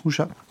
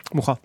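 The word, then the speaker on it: Mucha: Alphonse Moucha. Moucha. Ça dépend comment tu le prononces. Tu peux le prononcer à la française. Avec un che. – Bah, tu le prononces à la française, ça un che.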